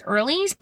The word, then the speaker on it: Earley: And remember, my last name is Earlys, but it's spelled E-A-R-L-E-Y.